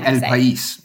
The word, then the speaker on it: El País: El País.